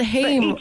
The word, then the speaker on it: HAIM: Is it Haim? – Haim, goddammit.